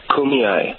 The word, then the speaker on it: Kumeyaay: So we paid attention to Paul and his singing and Paul and his work with the Kumeyaay.